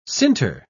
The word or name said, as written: sinter